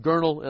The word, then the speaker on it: Gurnall: Packer says it, William Gurnall. We have known him as William Gurnall. The Englishman says it, William Gurnall, and I'm trusting he knows the right pronunciation.